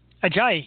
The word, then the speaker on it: Ajayi: I got an, I can't say his name right. Ajayi. – Yes, yeah. Perfect.